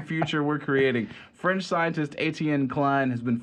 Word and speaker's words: Etienne: But E-T-I-E-N-N-E, Etienne? – Etienne? – Etienne? – Yeah. – Sure. – Etienne. – The French scientist Etienne Klein has... – Etienne Klein. – Etienne Klein has been forced to apologize... – Like a money machine, but with an N.